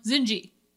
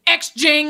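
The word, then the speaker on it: Xinji: Like one of our favorite new noodle places in Ohio City, and it's called Zinji, which is like X-I-N-J-I. I'm like, Zinji, not hard, two syllables. He's always like X-Jing, and I'm like, no, that's not even remotely. There's no G.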